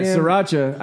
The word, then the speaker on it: sriracha: By the way, sriracha. I don't pronounce it that way. – Why? – I pronounce it Sriracha. – The way it's spelled.